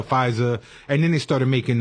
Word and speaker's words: Pfizer: I don't know if I'm pronouncing this right, but Pfizer? – Pfizer. – Yes. – The P is Puerto Rican.